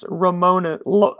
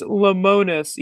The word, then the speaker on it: Lemonis: Should we still use lemon-themed social media posts for Chris Lemonis, even though the lemon part of Lemonis is pronounced lemon? – It's Lemon.